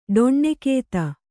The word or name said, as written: ಡೊಣ್ಣೆಕೇತ